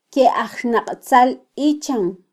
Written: kyeajxnaqtzalichan

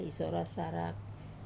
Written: isora sarak

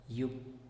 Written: yub

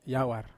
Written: awar

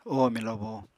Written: oho milobo

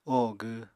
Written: oho gw

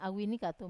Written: awini katu

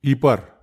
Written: ipar

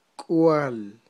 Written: k'wal

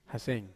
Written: hasen

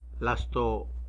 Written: lasto

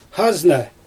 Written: xazna

